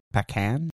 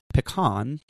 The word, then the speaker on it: pecan: The name pecan is derived from the Algonquin word pecan, P-A-K-A-N, meaning a nut that requires a stone to crack. Now, it's pronounced pecan, not pecan, which refers to a can that you pee in, clearly. Early French explorers documented this term as pecanes, P-A-C-A-N-E-S, and that influenced our modern-day nomenclature for the tree.